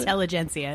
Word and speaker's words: Intelligentsia: If I wanted to watch a bunch of white guys in vintage clothes working on computers, I go to Intelligentsia. Is that how you pronounce it? – Intelligentsia.